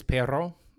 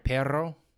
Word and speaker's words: perro: Like the word dog is perro. Sorry, perro.